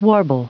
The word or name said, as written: warble